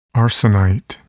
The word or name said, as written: arsenite